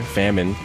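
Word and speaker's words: Famen: Famine, although it's spelled with an E, F-A-M-E-N, Famine, from Atlanta.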